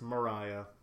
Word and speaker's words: Maria: It's spelled Maria, but pronounced Mariah.